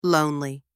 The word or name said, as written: lonely